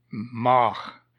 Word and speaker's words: Mach: The first way I spoke of it, it's spelled in English as M-A-C-K, but in German it is M-A-C-H, in both instances pronounced Mach or Mack.